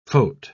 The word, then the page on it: phot-: British English